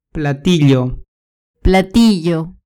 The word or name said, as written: platillo